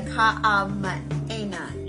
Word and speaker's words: Kamehameha: Now we have the Kamehameha. – Yes, you said that right. Correctly. – Kamehameha.